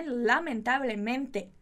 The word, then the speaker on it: lamentablemente: Muito cuidado com a pronúncia dessa palavra, né? Lamentablemente. Lamentablemente. Você não vai falar miente. Muito cuidado, o espanhol tem muito dessas, né? De mente e miente.